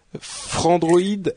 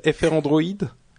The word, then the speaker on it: Frandroid: Non, Frandroid. Il y a pas, comme j'ai déjà dit, il y a pas de prononciation officielle, donc on entendait FR Android et Frandroid, mais d'accord. Au final, ça revient à la même chose.